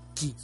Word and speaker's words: chi: Bueno, pues porque el dragón es la máxima eh, deidad china de la energía, del chi se escribe chi, se pronuncia ki.